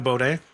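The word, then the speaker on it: Beaudin: Hi, my name's Drew Baudet.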